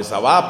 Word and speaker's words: Saba: Entonces el esplendor en la época de Salomón fue magnífico, y fue cuando fue la reina de Saba, o de Sabá, pronuncian otros, la reina de Saba, y quedó admirada de todo lo que había ahí.